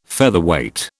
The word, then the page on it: Featherweight: British English